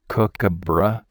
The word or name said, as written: kookaburra